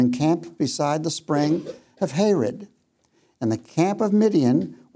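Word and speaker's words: Harod: That's how you pronounce it, Hared.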